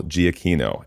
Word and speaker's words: Giacchino: Soft G, hard K. Kino, not Chino. So, indeed, Michael Giacchino.